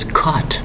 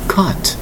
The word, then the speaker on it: cot: Today's word is caught. Caught. Notice the spelling of the single syllable word. C-O-T is the spelling. C-O-T.